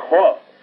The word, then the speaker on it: Hod: Y a Hod, se escribe H-O-D, Hod.